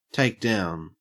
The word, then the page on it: takedown: Australian English